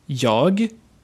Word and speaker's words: jag: That means that when you say jag, it does sometimes sound like ja.